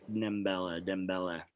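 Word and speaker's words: Dembélé: So, since we're talking about how good how good Mbella is. – You don't need, I don't think you pronounce the N, or it's like a really soft, like nasal, almost like Dembella.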